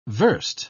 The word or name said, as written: versed